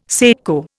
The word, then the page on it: secco: Indian